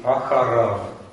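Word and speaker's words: ахарав (אחריו): Читаем «камац» и «вав». Йод не читаем. – Ясно. – То есть произносим «элав», «алав», «тахтав» и «ахарав». Йод остается только на письме.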